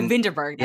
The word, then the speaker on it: Vinterberg: Oh, Vinterberg. That's how you say his name. – Yeah, Vinterberg.